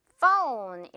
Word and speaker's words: phone: phone 발음할 때 그리고 fun이라고 하시는 분들도 봤어요. fun 아니고요. 재밌는 거 아니고요. phone 이렇게 O 발음 끌어주면 돼요.